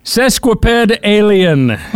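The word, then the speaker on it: sesquipedalian: I guess that's a horrible pronunciation. – It is? – Yeah. – How is it said correctly? – Sesquipedalien.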